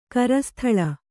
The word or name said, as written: ಕರಸ್ಥಳ